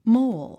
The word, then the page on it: mole: móul; モォゥ